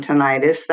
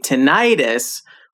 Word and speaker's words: tinnitus: The emphasis is on the first syllable, tinnitus? – Actually, it can be pronounced either way. Both are considered correct. As a matter of fact, you will notice I often switch back and forth between the two pronunciations. – That's funny. – Yeah, and I've also heard that British people tend to emphasize the first syllable, but many Americans, including me, say tinnitus with the emphasis on the middle syllable.